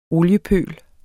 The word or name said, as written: oliepøl